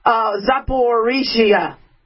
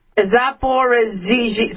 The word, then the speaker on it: Zaporizhia: And now I can't pronounce it. Zuzapetia, the latest, Zaporizhia. Zaporizhia. Sorry.